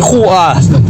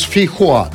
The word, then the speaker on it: фейхоа: Хоа пишется через О, Хоа, а не Хуа. Так что одна буква совпадает. Чего-чего? Это Хо через О. А, про фей Хуа, что ли, он там? Ну? А, там она пишется через О? разумеется, и произносится через О.